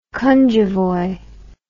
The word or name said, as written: cunjevoi